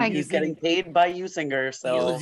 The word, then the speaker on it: Usinger: And they had the Bob Uecker commercial on the radio where he says it, and he's the spokesperson, and he says Usinger. – Ooh, the hard G, not the hard JG.